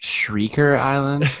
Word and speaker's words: Shrieker Island: Tremor Shriker Island. – Wow. – I want to watch. I want to watch that. – I believe it's pronounced Shrieker Island.